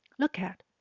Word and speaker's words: look at: Look at 是一个片语动词，是指短时间去专注着看某样东西。 Look 跟 at 可以连音，念成 look at，比较好念哦。